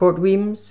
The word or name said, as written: Port Wemyss